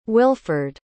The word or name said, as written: wilford